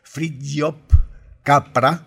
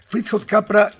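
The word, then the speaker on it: Fritjof Capra: Yo lo pronuncio así, algunos le dicen Fridtjof Capra. Es un austríaco y yo le propongo que lo escuchemos. Fridtjof Capra es autor de un libro muy famoso, El Tao de la Física.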